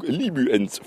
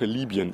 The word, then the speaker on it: Libyen: Bundesverteidigungsminister de Maizière hielt den Einsatz deutscher Soldaten zur Stabilisierung Libyens für möglich. Wie war das? Öffentlich-rechtliche Aussprache Libyen übrigens. Herrn Außentrottels Aussprache Libyen. Mit Ü.